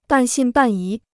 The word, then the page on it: 半信半疑: bàn xìn bàn yí